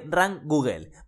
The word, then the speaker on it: Rankoogle: También estoy muy contento porque hace poco lancé eh, Rank Google, ¿vale? Se escribe R-A-N-K-O-O-G-L-E. Es como Rank Google, pero sin la primera G.